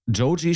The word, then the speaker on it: Joji: Joji schreibt man eigentlich so, wie man es spricht, O-J-I.